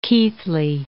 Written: Keighley

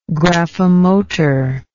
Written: graphomotor

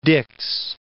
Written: Dix

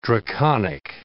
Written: draconic

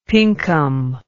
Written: Pinkham

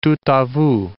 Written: tout a vous

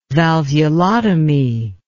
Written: valvulotomy

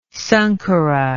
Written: Sankara

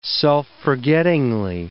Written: self-forgettingly

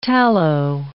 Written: tallow

